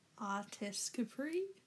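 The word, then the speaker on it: Artescapri: How would you say that? – Artis Capri? – Okay, cool.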